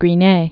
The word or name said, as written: Gris-Nez